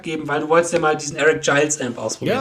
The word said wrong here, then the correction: Gales: Gales. – Eric Gales wird es ausgesprochen. Gales ausgesprochen. – Er wird tatsächlich Gales ausgesprochen.